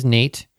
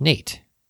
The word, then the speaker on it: Nate: So, for example, my name is Nate. So, what is a word that rhymes with Nate? Well, Kate, hate, plate. There's a lot of words that rhyme with Nate.